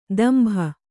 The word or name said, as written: ದಂಭ